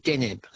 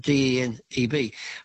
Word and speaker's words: Deneb: with its bright star called Deneb. It's spelled Deneb, not too far above the northern horizon.